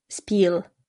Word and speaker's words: Spiel: Y E en alemán se lee I largo. Spiel.